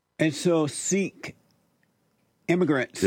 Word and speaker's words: Sikh: So we pronounce it Sikh.